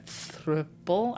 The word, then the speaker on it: thruple: So that's sort of our situation, but it's not like a thruple. I never know how to pronounce that word. – Thruple. – Thruple. So it's like couple with a thruh? – Yes.